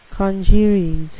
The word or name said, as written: congeries